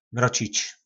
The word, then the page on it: Mrocic: po nasimu